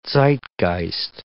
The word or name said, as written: Zeitgeist